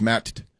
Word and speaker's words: Matt: I actually pronounce both the T's. She's like, what do you mean? I said, it's Matt.